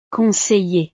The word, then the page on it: conseiller: frances